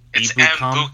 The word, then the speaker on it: Ebukam: It's Ebukam.